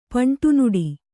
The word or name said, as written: ಪಂಟುನುಡಿ